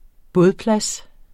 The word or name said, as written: bådeplads